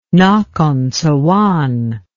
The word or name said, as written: nakhon sawan